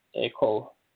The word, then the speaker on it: Ekow: I think this was posted on a brother's channel on YouTube called Echo Simpson. And his first name is spelled E-K-O-W Simpson. So if you search up Echo Simpson on YouTube, you will be able to see a couple of the videos that are showing the land.